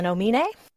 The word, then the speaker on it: Omine: I'm Carolyn Omine. – Omine. We've been saying Omine. Her name wrong this entire time.